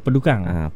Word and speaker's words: pedukang: Tapi tak tahu pedukang daripada dia. Biasa dengar juga. – Orang lama-lama pop-pop menangkan misalah. – Sebut kan? Pedukang. – Pedukang. – Pedukang. – Pedukang ni ejaan dia P-E-D-U-K-A-N-G.